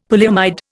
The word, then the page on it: polyamide: Indian